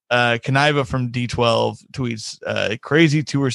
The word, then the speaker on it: Kniva: So it is Kniva, right? That I heard him say it like five times today. I'm pretty sure that sounds right. About right.